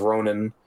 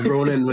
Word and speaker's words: Ronan: It's Ronan with N-A-N. – Oh, so it's like the Guardians one.